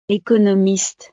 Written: economiste

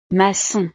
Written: maçon

